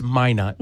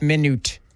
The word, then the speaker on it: Minot: It's Minot.